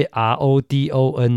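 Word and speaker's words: Rodón: 呃，他的父亲呢，在他五岁的时候呢，就移民到了美国的佛里达州的迈阿密啊，所以他的名字呢，发音是西班牙文呢、啊，所以 R O D O N 呢是念成 Rodon，那翻成中文的话呢，是比较接近罗当。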